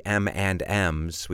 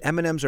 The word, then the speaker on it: M&M's: And remember, we pronounce this like it's an N. We don't say usually M and M's. We say M&M's, which, by the way, are pretty good, aren't they?